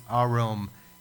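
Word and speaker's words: arom (ערום): but the word there in Greek, or Hebrew rather, is arom. In English, you would spell it A-R-O-M, arom.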